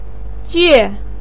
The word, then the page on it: jue: 4